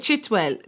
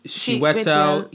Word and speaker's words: Chiwetel: His name is Chiwetel. – Isn't it Chitwell? – Chiwetel Ejiofor. – Wow.